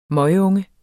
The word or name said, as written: møgunge